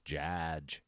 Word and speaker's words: Jadge: The bit for me is that anyone who addresses him in role-playing times, it's like, you're not saying it right. It's Jadge. It's Jadge.